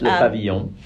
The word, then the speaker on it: Le Pavillon: Yes, Le Pavillon.